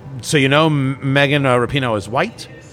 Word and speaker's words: Rapinoe: I want to get into Megan Rapinoe, and for the record, it is pronounced Rapinoe.